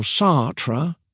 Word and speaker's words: Sartre: Jean-Paul Sartre, pronounced Sartre, was a French philosopher who lived from 1905 to 1980.